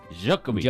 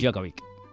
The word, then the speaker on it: Djokovic: Djokovic. – Djokovic. Bueno, ya de tres lo hemos dicho los tres. Tampoco debe ser tan meritorio, ni eres. – Porque estamos acostumbrados, pero si ves cómo se escribe...